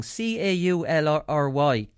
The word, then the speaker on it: Caulry: C A U L R Y Caulry. I never heard of that club before.